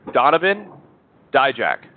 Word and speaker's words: Donovan Dijak: Donovan Dijak. Did I say that right, Sean? – Yes.